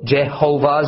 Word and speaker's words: Jehovah: The word Jehovah is, that is standard in Western Christianity is born out of a German way of spelling Yehovah. And then centuries ago was Englishized into our common Jehovah. Okay, so Jehovah is a pretty reasonable English language based pronunciation of God's name as long as we understand that saying Jehovah is the equivalent of calling a Russian person named Mikhail Mike.